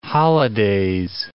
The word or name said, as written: holidays